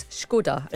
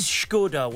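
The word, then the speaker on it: Škoda: O-D-A. How would you pronounce that? – Oh, so it's Skoda. – It is Skoda. Well done. But too many people say Skoda.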